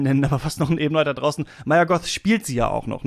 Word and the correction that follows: Mia Goth: Also es ist ja tatsächlich auch Mia Goth, spricht man sie so aus, ja.